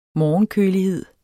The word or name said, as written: morgenkølighed